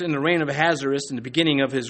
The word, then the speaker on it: Ahasuerus: You want to remember how to pronounce his name, just remember he has a wrist, Ahasuerus.